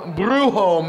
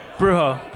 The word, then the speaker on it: Bruxo: And we have in the house from Brujo Mezcal. Am I pronouncing that right? Because I'm legendarily terrible at pronouncing things. – Yeah, yeah, no, that's right. Brujo.